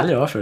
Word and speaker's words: Talhofer: João Marcelo Talhofer. Oh, Como é que fala? – Talhofer, acertou, cara.